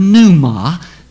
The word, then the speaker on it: pneuma: First of all, the Greek word that is there translated spirit is the word pneuma. The P is silent. P-N-E-U-M-A, pneuma.